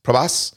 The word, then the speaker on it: Prabhas: Prabhas.